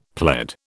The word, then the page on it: pled: British English